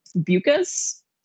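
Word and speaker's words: Beukes: So my third one is The Shining Girls by Lauren Bucus. I think is how you pronounce it. She said it rhymes with mucus.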